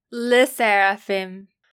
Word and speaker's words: SSERAFIM: SSERAFIM.